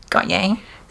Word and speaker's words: Gotye: Gotye.